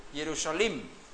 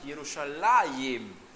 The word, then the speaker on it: Yerushalayim: Si hubiera sido plural Jerusalén, hubiera sido Yerushalim. Pero es dual, por eso decimos Yerushalayim.